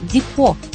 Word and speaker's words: депо: А еще обратите внимание, звук «где» в этом слове произносится мягко – «депо» хотя такое произношение сегодня вряд ли услышишь.